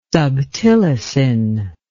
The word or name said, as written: subtilisin